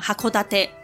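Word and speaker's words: 函館: 同一年，箱馆改名为现在使用的函馆，不过发音都没有变，就是一样哈库达特。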